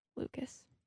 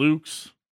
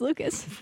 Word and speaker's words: Lukes: But they did say that it is pronounced Lucas.